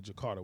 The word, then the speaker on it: Jakarta: I got to go to Jakarta, which is Indonesia.